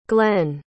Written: glenn

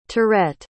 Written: tourette

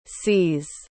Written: sees

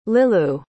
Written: liloo

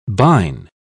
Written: bine

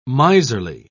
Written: miserly

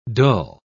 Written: dull

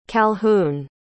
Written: calhoun

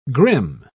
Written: grim

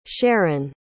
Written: Sheron

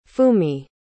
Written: fumi

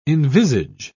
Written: envisage

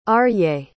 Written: Aryeh

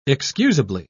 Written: excusably

